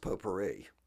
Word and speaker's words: potpourri: It's pronounced potpourri, but it's spelled pot party.